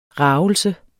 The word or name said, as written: ragelse